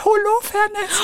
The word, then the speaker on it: Holofernes: Ich lese gerade die Lautschrift. Und? und die lautet Holofernes. So wie du es ausgesprochen hast.